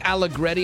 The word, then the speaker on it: Allegretti: He's an offensive lineman, Nick Allegretti. Am I saying that right, Zach? Gavin? – Yes. – I used to always go to Zach for sports stuff. – Yes, you are absolutely correct.